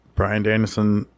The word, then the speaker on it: Bryan Danielson: Bryan Danielson.